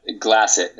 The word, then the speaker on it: Glassett: Glassett.